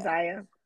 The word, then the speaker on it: Zaya: Yeah, Zaya.